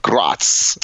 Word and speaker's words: Graz: Those are all studies that the University of Graz is working on in Austria.